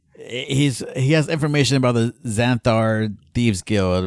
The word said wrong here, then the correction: Xanathar: That was a really good pronunciation, I gotta say. It's Xanathar. – Xanathar.